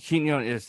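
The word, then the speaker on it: Quiñones: How do you say his name? Pete Quinn. It starts with a Q. – Oh, Peter Quinones. – Pete Quinones. There we go.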